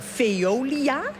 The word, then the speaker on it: Veolia: Want wij gaan zo naar Veolia, zeg ik dat goed? – Ja, dat zeg je heel goed.